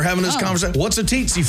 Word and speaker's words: tsetse: No, it's a tsetse fly. – It's not spelled that way. – It's spelled T-S-E-T-S-E. – Tsetse. That doesn't look like tsetse to me. It looks like tsetse in the T.